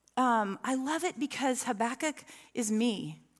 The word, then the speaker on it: Habakkuk: Habakkuk is, and that's how you pronounce it.